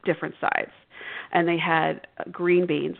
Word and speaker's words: Gruene: It's called Green, Texas. It's spelled G-R-U-E-N-E. It's German.